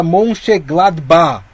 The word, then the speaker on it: Mönchengladbach: Teremos aí no grupo B Real Madrid e Shakhtar Donetsk, esse jogo na Espanha, Inter de Milão contra o Borussia, o Borussia Mönchengladbach.